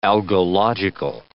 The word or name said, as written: algological